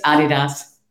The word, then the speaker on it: Adidas: Because we always grow up saying Adidas. – It's Adidas.